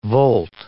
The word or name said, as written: volt